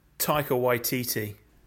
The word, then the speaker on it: Taika Waititi: No, Taika Waititi.